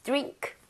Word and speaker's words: drink: 자, 음료 발음을 할때 드링크는 좀 그렇죠, 그죠? – 예. – DR, DI가 있어요. I는 모음이잖아요. 보통 DR, D에 이렇게 모음이 있으면 DR이 충돌을 하면서요. 즉, 비슷합니다. 똑같다는 건 아니에요. – 네. – 그 얘기는 R 발음을 제대로 하고 있다라는 얘기입니다, 사실은요. 그래서 드링크가 아니라 이 모양 좀 모아서 drink 이렇게 가는 게더 자연스럽죠.